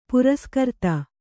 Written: ಪುರಸ್ಕರ್ತ

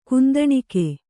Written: ಕುಂದಣಿಕೆ

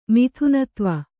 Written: ಮಿಥುನತ್ವ

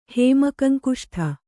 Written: ಹೇಮಕಂಕುಷ್ಠ

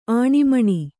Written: ಆನೆಮೀನು

